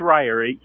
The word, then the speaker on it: Rahier: It's either Rahier or Ryer. – Ryer? – Oh, Ryer. – Probably Ryer. – Okay. How do you pronounce it? Oh, she's got her headphones in. – It's Ryer.